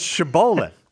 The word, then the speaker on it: shibboleth: That's not how you pronounce that. It's shibboleth.